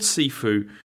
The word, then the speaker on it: Sifu: Well, okay, it's called Sifu, but then this must be like Cantonese pronunciation or whatever, because in Mandarin this is like shuffle, and so every time I look at this it's like it annoys me slightly, but then okay.